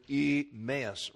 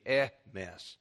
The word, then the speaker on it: Emmaus: Now, I've been pronouncing the word Emmaus wrong for years. If you notice, that's a short E. It should be Emmaus.